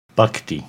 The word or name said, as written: Bahkti